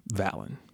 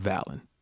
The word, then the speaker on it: Valen: Now, for me, it's a little weird because my name actually happens to be Valen. Not Valentine, but Valen. Spelled differently, pronounced the same, but you can't say Valentine without saying Valen.